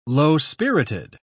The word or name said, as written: low-spirited